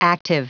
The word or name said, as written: active